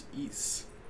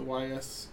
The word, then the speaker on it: Ys: I figured out how to pronounce this, by the way. It's pronounced Ys. The Y-S.